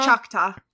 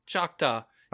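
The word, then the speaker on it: Choctaw: Chakna? – Chakta. – Chakta.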